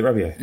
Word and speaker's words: Rabiot: How do you pronounce Rabiot? Is it Rabiot? – Exactly, like that. – Alright, wicked. – How else can you pronounce it? – Rabiot. – I mean, you're some sort of a Luddite if you're pronouncing a French name with a T on the end.